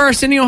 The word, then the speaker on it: Arsenio: How do you say that name? Arsenio? – Arsenio. – I've never heard. – Come on. Remember Arsenio Hall?